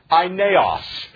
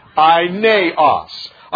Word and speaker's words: Aeneas: And a man named, it looks like Aeneas, and it's really pronounced Aeneas. Can you say it with me? Aeneas.